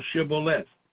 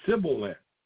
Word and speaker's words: Shibboleth: And if he said nay, then said they unto him, say now Shibboleth. And they said Sibboleth, for they could not frame to pronounce it aright.